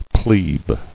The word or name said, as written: Plebe